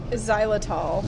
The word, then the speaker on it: Xylitol: Xylitol.